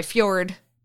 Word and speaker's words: Fjord: He plays Ford, the most reasonable and wonderful of all of us. And it's not how you pronounce it. – Pronounce what? Oh, Ford? Sorry, Fjord.